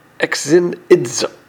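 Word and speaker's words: Xenizo (ξενίζω): And this word is an interesting word because it's the word it's pronounced Xenizo. It's pronounced X-E-N-I-Z-O, X-N-E-Z-O, and that means to find it strange, to receive something.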